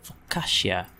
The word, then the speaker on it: focaccia: Focaccia. Is that how you say it? Focaccia. Pronounce. Let's ask how to pronounce it. How to pronounce focaccia? F-O-C-C. Focaccia. Okay, YouTube says focaccia.